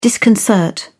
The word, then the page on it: disconcert: British English